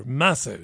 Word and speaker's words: Matthau: Walter Matthau, that's how you pronounce it. I think that's right.